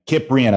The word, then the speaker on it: Cyprian: You probably call him Cyprian. Cyprian, it's a hard C.